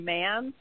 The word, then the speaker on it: Manns: You can also follow me on Twitter, Facebook, and Instagram at I Am Lori Mans.